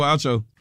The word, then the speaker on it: Acho: How do you say? – Acho. Uh-huh. – Yeah, Emmanuel Acho.